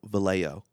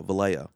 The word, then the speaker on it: Vallejo: It's not ho, Vallejo. – But it's with a J-O. – Yeah, I know. I'm telling you, though, Vallejo.